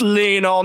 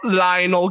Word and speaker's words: Lionel: remember when like Brett made a TikTok about Messi, and it's like it's pronounced Lionel, not Lionel. Get it right. I'm like, Jesus Christ. It's not Lionel Richie.